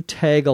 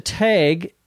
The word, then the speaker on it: tag: I should have known better than to let you tag along. What was I thinking?" To tag, T-A-G, some would pronounce it tag, but in my Minnesota accent it's tag.